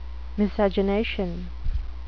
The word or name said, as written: miscegenation